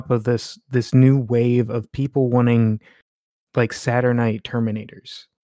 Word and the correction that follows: Saturnine: Or Saturnine, excuse me. I always mispronounce that.